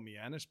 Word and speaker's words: Mianus: I've never mentioned this on the show, but Exit 5 on 95 South is a town called Mianus, but it's spelled my anus.